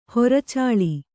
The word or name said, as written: ಹೊರಚಾಳಿ